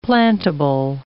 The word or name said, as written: plantable